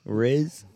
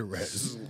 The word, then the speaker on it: rizz: R-I-Z-Z. – That sounds like y'all saying with an E, motherfucker. – But you said it right after we corrected you the first time, and then this motherfucker just slowly changed it back to Rez. Riz.